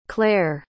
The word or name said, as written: clair